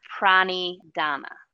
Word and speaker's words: Pranidhana: Ishvara Pradhana. – Close. Ishvara Pranidhana.